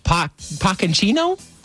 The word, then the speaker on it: Pochettino: Mauricio Pochettino. – Pochettino? – Pochettino.